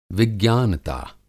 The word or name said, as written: विज्ञानता